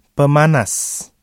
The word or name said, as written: pemanas